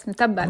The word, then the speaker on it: mutabal: And the first, which I think really speaks to this phenomenon you're talking about, is a cousin of, please correct my pronunciation, mutabal, mutabal. – Oh yeah, very good pronunciation. Yes, mutabal.